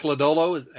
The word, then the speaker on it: Lodolo: And that's Nick Lodolo. Have we figured out that we're pronouncing that correctly? – We are.